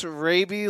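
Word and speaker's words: Rabe: But she said it's pronounced Raby like the raccoon.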